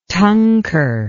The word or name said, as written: tunker